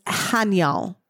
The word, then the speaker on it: Haniel: The next one, and I never said this right, so it's Haniel. H-A-N-I-E-L.